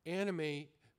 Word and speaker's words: anomie: a time when all people are experiencing Anime may be a word that's unfamiliar to you. I'll spell it for you. A-N-O-M-I-E. Anime.